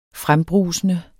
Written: FREMBRUSENDE

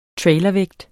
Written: trailervægt

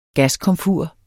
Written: gaskomfur

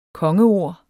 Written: kongeord

